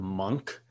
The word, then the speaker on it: Munch: I know you wouldn't think there's a G in his last name, but it's M-U-N-C-H is how you spell it. And it's actually pronounced, I believe it is M-U-H-N-G-K is how it's pronounced.